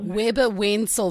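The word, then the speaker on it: Webber Wentzel: Weber Wenzel, did I say that correctly? – It's Weber Wenzel. – Weber Wenzel.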